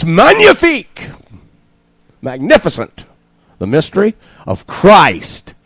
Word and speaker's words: magnifique: Susie told me it's not magnifique, it's magnifique because that is French. You don't pronounce the G.